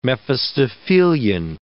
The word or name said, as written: Mephistophelian